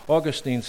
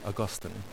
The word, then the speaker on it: Augustine: Augustine said this, or as the British say, Augustine, said this: What does it look like?